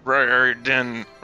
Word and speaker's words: Riordan: No, we've been reading Percy Jackson and the Olympians and the Stolen Lightning by Riordan. How do you pronounce his name? – Riordan.